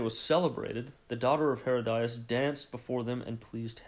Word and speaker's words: Herodias: So verse 3, "For Herod had laid hold of John and bound him and put him in prison for the sake of," I believe it's pronounced Herodias. So "for Herod had laid hold of John and bound him and put him in prison for the sake of Herodias, his brother Philip's wife, because John had said to him, 'It is not lawful for you to have her.'